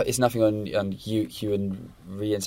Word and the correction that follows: Rheon: How you say his name? Iwan Rheon, the actor.